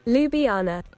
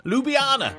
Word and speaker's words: Ljubljana: I think it is pronounced Ljubljana. Is it Ljubljana? Can I have a pronunciation check, please? – Ljubljana. – Ljubljana. That's it.